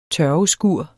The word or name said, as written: tørveskur